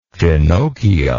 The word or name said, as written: finochio